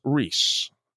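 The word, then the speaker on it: Reis: By the way, my last name is pronounced Reese. Hashtag Reese Puff. Okay, so thanks for the vernacular lesson, Reese. I said it right the first time, sir, because it was R-E-I-S. I'm an intellect. I know how to read. But thank you. It's like Reese's Peanut Butter Cups, one of my favorite peanut butter cups there are.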